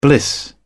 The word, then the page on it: bliss: British English